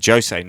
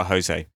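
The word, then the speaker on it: José: I'm going to correct you on some pronunciation there because it's Portuguese. It's a Jose, not a Jose.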